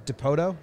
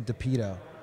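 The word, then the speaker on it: Dipoto: If I was DePoto, is how you say it? – Yeah. – I always think I'm going to say it wrong. Same with Scott Servais. – Service. – I always, all the names. – How do you say DePoto wrong? – I was going to say DePito.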